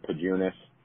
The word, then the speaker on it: Pajunas: I also have a Twitter page that's just at Clayton Pajunas. My last name is spelled P-A-J-U-N-A-S.